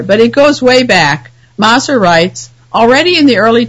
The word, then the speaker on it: Maser: Werner Maser, I've learned his name is pronounced Maser, not Maser, he